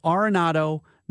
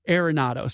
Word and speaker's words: Arenado: Trying to tell everybody out there it's Arenado, not Arenado.